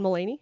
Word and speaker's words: Mulaney: I would love to direct this person to listen and watch John Mulvaney. – John Mulaney? – Mulaney, that's the guy.